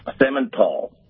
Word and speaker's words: semental: Semental. – So you got to really go tall on the end. You got to give it a little juice on the end to say it properly.